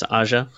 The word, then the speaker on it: Aja: Like, how do you pronounce it? It's Aja.